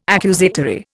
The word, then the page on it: accusatory: Indian